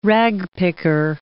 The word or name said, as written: ragpicker